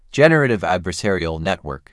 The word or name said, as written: Generative Adversarial Network